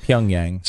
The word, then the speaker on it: Pyongyang: Pyongyang.